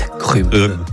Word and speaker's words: agrumes: Voilà, les agrumes. On ne prononce pas le S.